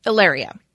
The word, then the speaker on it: Hilaria: He also got married not too long ago, remarried to a woman named Ilaria, spelled Hilaria, but pronounced Ilaria.